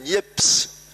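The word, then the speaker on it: Niépce: Il y a la transcription des noms dans les documents militaires, ils sont écrits N-I-E-P-S. Et ça veut bien dire que c'était prononcé Nieps, puisque la façon d'écrire, c'était phonétique. Donc les gens disaient Nieps, N-I-E-P-S, et pas C-E.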